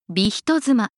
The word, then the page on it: bi-hitotsuma: Japanese